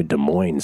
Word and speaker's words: Des Moines: By the way, I can't get over the Arkansas, Illinois, Des Moines silent S thing.